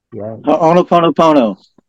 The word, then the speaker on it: Ho'oponopono: It's Ho'oponopono, I think it is. – Ho'oponopono. – Yeah, that's the one.